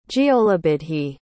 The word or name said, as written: giollabuidhe